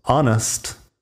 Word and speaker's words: honest: Un par de palabras más con la H al principio que no suena. Tenemos honest de honesto, hour de hora, honor de honor.